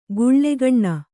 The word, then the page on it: ಗುಳ್ಳೆಗಣ್ಣ: guḷḷegaṇṇa